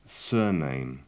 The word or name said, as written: surname